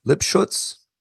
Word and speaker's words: Lipschutz: Well, I saw that a lot of the tunes are co-written by you working with Kurt Lipschutz. Did I say his name right? – Yeah, exactly. Yeah, exactly.